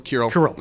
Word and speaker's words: Kirill: I came across pronounce the name again for me. – Oh, Patriarch Kirill. – Kirill. Yeah. Kirill. Kirill. – American pronunciation, we're fine either way.